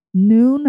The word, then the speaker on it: Nuun: Hammer, Noon, and that's spelled N-U-U-N. Salt Stick, Scratch, that's S-K-R-A-T-C-H. Tailwind, T-A-I-L-W-I-N-D. Ultima, and Martin. And Martin is spelled M-A-U-R-T-E-N.